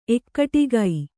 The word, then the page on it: ಏಕಟಾಕಿ: ēkaṭāki